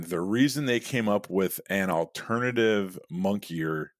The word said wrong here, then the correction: moniker: moniker, I'm sorry, you can tell I learned that word through reading because I mispronounced it, which is nothing to be ashamed of because all it does is show that I read.